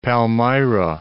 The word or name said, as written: Palmyra